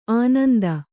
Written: ಆನಂದ

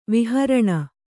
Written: ವಿಹಾರಿಣಿ

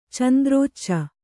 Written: ಚಂದ್ರೋಚ್ಚ

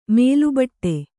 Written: ಮೇಲುಬಟ್ಟೆ

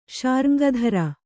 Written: ಶಾರ್ಙ್ಗಧರ

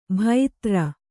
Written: ಭೈತ್ರ

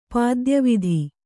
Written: ಪಾದ್ಯವಿಧಿ

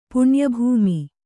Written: ಪುಣ್ಯಭೂಮಿ

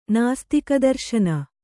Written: ನಾಸ್ತಿಕದರ್ಶನ

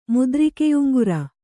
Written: ಮುದ್ರಿಕೆಯುಂಗುರ

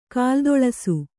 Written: ಕಾಲ್ದೊಳಸು

